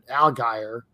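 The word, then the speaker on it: Allgeier: And the other running back they have, and I'm going to butcher his name all week, but Al Geyer, I think that's how it is. – Algier, one of them.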